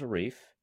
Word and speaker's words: Arif: I mean, my name is Arif, and that rhymes with wreath.